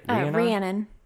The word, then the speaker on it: Rhiannon: Rhiannon.